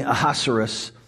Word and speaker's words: Ahasuerus: Now, last week we'd studied that King Ahasuerus, who is the seedy sort of Persian like ruler, and if you say his name fast enough, it sounds like King, are you serious?